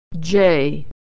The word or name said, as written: Jay